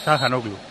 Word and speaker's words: Çalhanoğlu: Pero yo le pregunté a un amigo de Arda Turán y me dijo que era Çalhanoğlu. – Çalhanoğlu. Sí, pero. Es que la H se pronuncia.